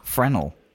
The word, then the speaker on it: Fresnel: By the way, you don't pronounce the S. I even pronounced the S in reading this question out. – So what is it? Fresnel? – Fresnel.